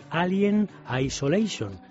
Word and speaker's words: Alien Isolation: Uno de ellos, el esperado Alien Isolation.